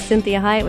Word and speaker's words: Cinthia Hiett: This is Cinthia Hiett with Conversations with Cinthia.